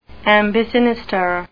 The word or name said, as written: ambisinister